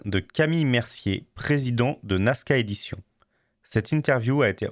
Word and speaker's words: Nazca: et je suis avec euh, Camille de Nasca Édition. – Je me trompe pas dans la prononciation. C'est exactement ça.